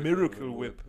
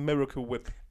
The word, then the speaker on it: Miracle Whip: Jo, Alter, Miracle Whip wird das in Deutschland ausgesprochen und es das heißt einfach Miracle, Miracle Whip.